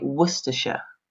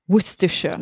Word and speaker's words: Worcestershire: We don't kind of pronounce a hard C. So we would say Worcestershire. – Worcestershire.